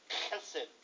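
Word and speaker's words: chesed (חסד): This word steadfast love is chesed in Hebrew. It is spelled H-E-S-E-D. But according to my younger son who studied Hebrew, he's smarter than me. He said, Dad, it's pronounced chesed because it's Hebrew.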